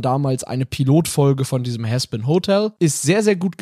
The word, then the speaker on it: Hazbin: Haspin mit H-A-Z-B-I-N, richtig? – Ja, genau. Aber ausgesprochen Haspin aus offensichtlichen Gründen.